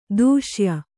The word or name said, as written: ದೇಶೀಯ